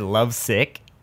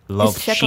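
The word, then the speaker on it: Lovcik: Yeah, I said love sick.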